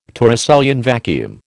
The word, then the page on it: Torricellian vacuum: American English